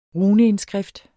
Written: runeindskrift

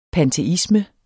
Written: panteisme

